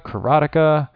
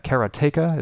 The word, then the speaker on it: Karateka: Now, there are, of course, other possibilities like Prince of Persia, Karateka. No, I'm sorry, I mispronounced that. Karateka, is that correct? – Karateka.